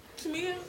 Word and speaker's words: Tamia: Tamia. – Tamia.